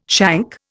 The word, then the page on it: shank: Indian